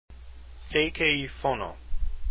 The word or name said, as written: Szekelyfono